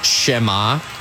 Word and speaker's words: Xamax: Well, it's pronounced Schema, X-A-M-A-X, in the Swiss League. I did not know how to pronounce it until Marin Haile Selassie got to the Fire, and he told me because he used to play there.